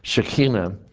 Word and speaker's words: Shekinah: It was known as the presence, the glory of God and His presence, in the Hebrew Shekinah, or the correct pronunciation is Shekinah, and I got to get the guttural thing in there, Shekinah.